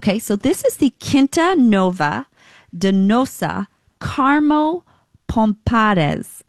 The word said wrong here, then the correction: Pomares: Okay, I'm going to say that again because my pronunciation, as you know, the Quinta Nova Carmo Pomares. Sorry, Pomares. P-O-M-A-R-E-S. $22.